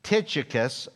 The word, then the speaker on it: Tychicus: Now, I should use the English spelling for my cue as to how to pronounce this word. Tychicus is how I would do it in English, but that's just not the Greek word.